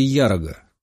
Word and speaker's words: Ярога: Не хотим потерять подающего надежды Ярога». Он сделал ударение на первый слог.